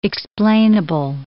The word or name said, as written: explainable